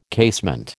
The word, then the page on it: casement: American English